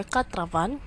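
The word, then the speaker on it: quatre-vingts: So you don't have to pronounce the S. So we have here katravan, katravan, katravanun, or katravanun, katravandu, katravandu, katravantua, katravantua.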